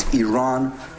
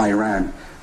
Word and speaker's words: Iran: First of all, you say you support the Iranian people, but you're pronouncing our country's name wrong. Our country is named Iran, not Iran.